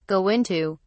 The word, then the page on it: go into: British English